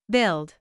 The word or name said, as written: build